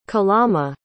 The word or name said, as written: kalama